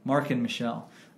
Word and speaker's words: Marken Michel: I think your boy Marcon Michel, did I pronounce that? – Well, Marcon, Markin. – Marken Michel uh, was open.